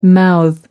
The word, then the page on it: mouth off: British English